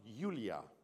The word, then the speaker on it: Julia: It's actually spelled J-U-L-I-A, but officially, according to her dad's background, it's pronounced Yulia.